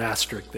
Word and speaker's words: asterisk: But with an asterisk there, we have to, I pronounce that word wrong all the time. I don't know why that came to my mind. Someone always corrects me. I don't know. So how do you say it? – Asterisk.